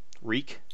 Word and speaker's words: Reek: His name is Reek. It rhymes with Meek.